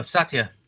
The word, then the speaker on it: Satya: Oh, Satya.